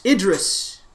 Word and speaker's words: Idris Elba: they were also saying that they would like, is it Idris Elba? Is that how you say his name? – Yes, Idris. – Idris.